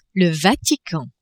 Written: Vatican

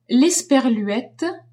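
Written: l'esperluette

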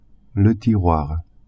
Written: tiroir

